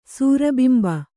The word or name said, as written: ಸೂರಬಿಂಬ